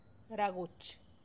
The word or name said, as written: ragutx